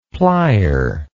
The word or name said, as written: plyer